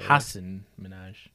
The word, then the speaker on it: Hasan Minhaj: You pronounce it properly. Hasan Minhaj. – Is it Hasan? – No, it's Hasan. – Well, you pronounce his last name correctly. – Minhaj. – Yeah. – Yeah? Okay. But he makes a good point. If you can fucking pronounce Gyllenhaal, you can pronounce his name.